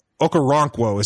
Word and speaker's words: Okoronkwo: Obi Okoronkwo is how you say his last name.